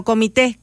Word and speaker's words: comité: tendrían que regresarlos a la escuela porque dice comité, no padre, comité de padres de familia, no comité, que por cierto lleva acento en la última E.